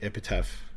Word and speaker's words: Epitaph: And they actually signed with Epitaph, mm-hmm.